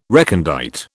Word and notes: recondite: British English

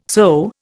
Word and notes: sew: Indian

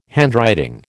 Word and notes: handwriting: American English

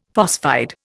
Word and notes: phosphide: Indian

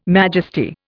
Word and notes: majesty: Indian

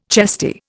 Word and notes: chesty: Indian

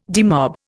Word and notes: demob: Indian

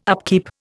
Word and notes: upkeep: Indian

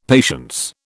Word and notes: patience: British English

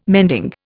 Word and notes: mending: Indian

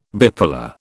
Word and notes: bipolar: British English

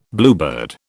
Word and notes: bluebird: British English

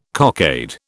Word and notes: cockade: British English

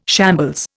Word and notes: shambles: Indian

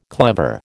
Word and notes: clever: American English

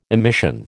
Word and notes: emission: American English

